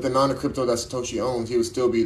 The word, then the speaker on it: Satoshi: Sakotis, um, Takoshi Sakanam. Uh, yes, um, Satoshi. There you go, Satoshi.